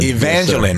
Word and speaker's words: Evangeline: It's Evangeline, like Jacqueline. – Evangeline.